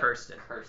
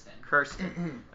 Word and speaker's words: Kirsten: Her actual nickname she emailed in is Kirsten. It rhymes with Sir. Okay, so I got it right. Yeah. Kirsten. – Kirsten.